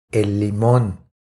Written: El limon

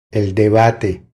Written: El debate

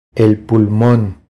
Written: El pulmon

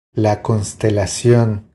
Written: La constelacion

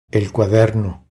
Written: El cuaderno

